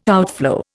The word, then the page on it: outflow: Indian